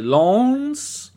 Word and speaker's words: Lens: The game is uh, at home to Lens. That's exactly how you pronounce it.